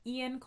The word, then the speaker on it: Eoin: The author's name, I think it's pronounced Ian Colfer. It's Irish. His first name is spelled E-O-I-N, so it really could be anything. His name could be Lucia, and it could be spelled that way.